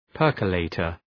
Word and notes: percolator: ‘pɜ:rkə,leıtər

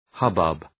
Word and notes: hubbub: ‘hʌbʌb

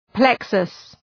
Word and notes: plexus: ‘pleksəs

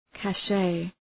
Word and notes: cache: kæʃ

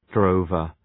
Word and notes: drover: ‘drəʋvər

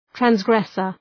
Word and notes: transgressor: træns’gresər

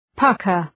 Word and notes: pucker: {'pʌkər}

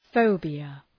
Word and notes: phobia: ‘fəʋbıə

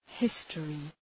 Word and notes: history: ‘hıstərı

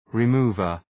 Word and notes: remover: {rı’mu:vər}

